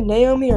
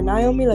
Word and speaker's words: Naomi: Like, I understand if you say Naomi or Naomi, like that's not a big deal. But no, Amy.